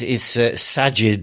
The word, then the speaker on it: Sajid: I feel like Savid... I always get his name wrong. – It's Sajid.